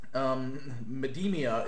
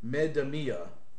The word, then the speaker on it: Medemia: All right, DJ Particle says, Medemia is pronounced with the loud accents on the me and the mi. Medemia. Think of the cadence of the brand name Metamucil as a mnemonic.